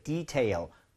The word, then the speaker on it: detail: L 발음은 혀끝이 항상 앞쪽을 향해야 됩니다. 구부려지면 절대 안 돼요. 윗니 입천장 사이에 코너에 힘을 주면서 앞으로 민다는 느낌 해볼게요. Detail, detail.